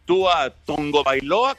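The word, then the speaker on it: Tua Tagovailoa: Pues ya le dieron el contrato, ya está firmado Tua Tongo Bailoa, como es la pronunciación correcta.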